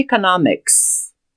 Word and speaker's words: economics: E-C-O-N-O-M-I-C-S. E-C-O-N-O-M-I-C-S. Economics. Não esqueça que a pronúncia da letra E em inglês é I. Então fica economics.